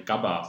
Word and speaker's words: GABA: Dazu gehört dieses Gamma-Aminobuttersäure, GABA, ausgesprochen.